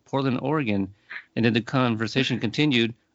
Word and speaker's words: Oregon: Did I say it right, by the way? – Oregon. – Oregon. – Yeah, we actually have a bumper stickers that is spelled Orygun, Oregon. Yeah, for that very reason.